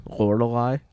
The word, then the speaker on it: Lorelai: There's a Lorelai. – Lorelai. – It's three syllables for you.